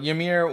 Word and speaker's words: Ymir: I say Ymir. – Yeah, Ymir. Ymir. A lot of different pronunciations, but I think the correct one is Ymir. – All right. So Ymir was greedily inhaling Adhumla's milk while new giants were being created from his sweat.